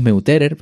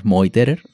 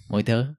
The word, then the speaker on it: Meuterer: Vamos, decimos Meuterer, pero en alemán es Moiterer, ¿no? La pronunciación. Moiterer.